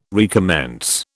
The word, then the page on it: recommence: British English